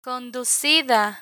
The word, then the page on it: conducida: Spanish